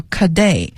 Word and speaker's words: cadet: 好，再往下是士兵这一个系列里面用到的词叫 cadet， C-A-D-E-T。那么 cadet 呢，你听这个发音，它就是一个法语词，对于英语来讲是一个外来的词，就是士兵的意思，里边的一个职衔，比较低的职衔。